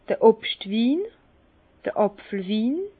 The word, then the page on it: d'r Obstwin, Apfelwin: Bas-Rhin; Herrlisheim